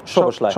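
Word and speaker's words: Szoboszlai: Csoboszlai.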